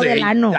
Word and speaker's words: Delano: Pero se dice Delano, ¿no? – Ah, yo no sé. – No, Delano.